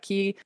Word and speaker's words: quai: And so, like, for example, the word key, which is Q-U-A-I, you pronounce that key.